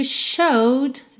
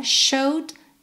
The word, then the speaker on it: showed: But still you showed the way. Cuidado aqui para não falar showed, né? A gente não pronuncia esse showed the way. Showed, passado de show, né? Só tirar o ed, mais um verbo regular aqui.